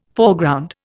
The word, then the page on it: Foreground: Indian